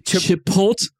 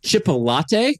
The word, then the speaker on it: Chipotle: The brand is called Chipotle. Chipotle. – All right. – In the past, everybody listening, I've heard him pronounce it as Chipolate. – That's actually a better one than I was doing right now. – Which is better than what he just said. – That's closer.